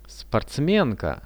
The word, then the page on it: sportsmenka: Russian